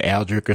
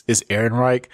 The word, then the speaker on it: Ehrenreich: I think it's Ehrenreich, but I've been saying Aldrich or something. I don't know, but it's Ehrenreich.